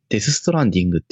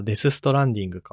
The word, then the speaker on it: Death Stranding: このデスストレンディング買うってのは何ああ、これ、デスストランディングって読むんだけど。 – あ、デスストランディングか。